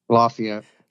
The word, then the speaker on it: Lafayette: Lafayette.